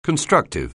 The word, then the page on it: constructive: anglais